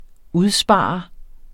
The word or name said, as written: udspare